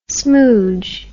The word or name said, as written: smoudge